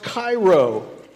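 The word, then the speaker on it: Cairo (χαίρω): It's this word, it's actually pronounced Cairo, kind of like the city.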